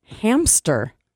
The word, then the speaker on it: hamster: How about hamster? – How do people mispronounce hamster? – Because they put a P in there. Hamster.